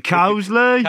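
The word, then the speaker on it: Coseley: Even the occasional cricket talk takes me back to my season's playing for Cosley. Is that the right pronunciation? Cosley? – Cosley!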